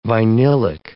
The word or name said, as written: vinylic